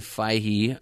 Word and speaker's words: Feige: But I think the point we really want to make is this is just a tremendous effort on the part of Kevin Feige, I think is how we decided it's pronounced.